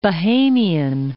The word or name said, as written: Bahamian